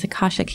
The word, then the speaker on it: Akashic: Another modality is Akashic healing. I said that right, right? – Oh, yeah.